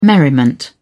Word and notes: merriment: British English

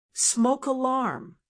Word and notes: smoke alarm: American English

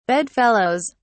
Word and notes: bedfellows: British English

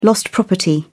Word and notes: lost property: British English

